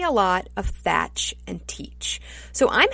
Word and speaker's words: Teach: So sometimes Blackbeard's name is written and pronounced Teach, like T-E-A-C-H, and other times it's written and pronounced Thatch, like T-H-A-T-C-H, like a thatch roof. I'm going to go against some of the leading experts here who pronounce it Thatch and say, I'm going to say Teach, and I'll tell you why.